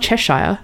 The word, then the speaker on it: Cheshire: It's not Cheshire. – Ch sounds hard. Cheshire is hard.